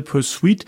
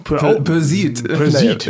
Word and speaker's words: Pursuit: Nee, Sie haben gesagt Pursuit. – Pursuit. – Ja, also mehr nach Persil als Pursuit.